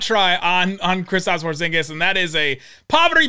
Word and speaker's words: Porzingis: The emphasis on the end of Porzingis is perfect.